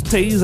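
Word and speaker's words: Toews: Donc, le ministre Vic Tease, j'ai entendu Tease, apparemment que ça se prononce différemment par différentes personnes, mais Vic Tease, le T-O-E-W-S, n'en a pas fini avec les remontrances des internautes.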